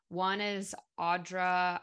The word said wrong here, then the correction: Audrey Nuna: I think her name is Audrey Nuna, is how you pronounce it,